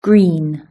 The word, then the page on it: green: british-english